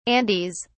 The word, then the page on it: andes: British English